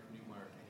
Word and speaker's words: Newmark: Who do you want, Eric Newmark, N-E-W-M-A-R-K, Mr. Gutierrez.